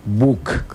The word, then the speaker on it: buk: Se pronuncia buk.